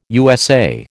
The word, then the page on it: USA: American English